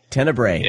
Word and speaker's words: Tenebrae: I finally watched, and I'm probably going to tell me if I'm pronouncing this wrong or not, but Tenebrae? – Tenebrae? – No. You can say Tenebrae? – Tenebrae. – Yeah, it's okay. Tenebrae. – There you go.